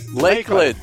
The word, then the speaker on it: Lakeland: Cigar Life, Lackland, Florida. – Lakeland. That's seven.